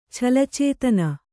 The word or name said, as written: ಛಲಚೇತನ